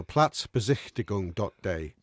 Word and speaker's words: Platzbesichtigung: It's platzbesichtigung.de. I'll get the Anfield. – That's excellent. – That's pronounced Platz. – Your German's excellent. – Felt like it was fantastic there on the... – Say it. Platzbesichtigung.de.